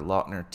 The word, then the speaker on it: Lautner: Abduction is a Taylor Lautner teen action film.